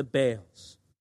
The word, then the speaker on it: Baal: I am aware, I'm almost certain that I mispronounced this and that the actual pronunciation of this God should be Baal. However, I've been saying Baal for so many years, I find it impossible to say Baal.